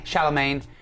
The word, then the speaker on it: Chalamet: Chalamet. No, there's no N.